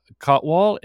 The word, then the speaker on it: Kotwal: We're grateful to have Dr. Anupam Kotwal.